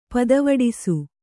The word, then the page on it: ಪತ್ತುವಿಡಿಸು: Kannada